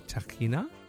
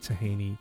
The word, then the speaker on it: tahini: Okay, so I've actually never got an actual verdict on this, but the product that I'm holding in front of me is called tekhina, and that's certainly how it's pronounced over in the Middle East. But here in Australia, people call it tahini.